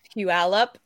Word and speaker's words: Puyallup: But while we're talking about your shows, how do you pronounce that one show? It's like Puyallup or something. How do you say it? – Puyallup. That's the name of the tribe that it's in that area.